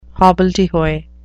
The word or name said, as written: hobbledehoy